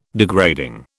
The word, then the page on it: degrading: British English